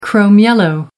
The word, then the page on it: chrome yellow: American English